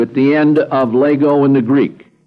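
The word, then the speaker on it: Lego (λέγω): Present tense for I say, it's pronounced Lego.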